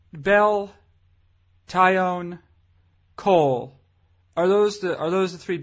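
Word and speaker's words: Taillon: How do you say it? – Tyone. – Like I'm going. Tyone. I'm going to tie on this rope to this stick. – No, you're going to own this tie.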